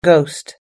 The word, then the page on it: ghost: American English